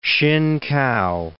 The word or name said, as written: Hsinkao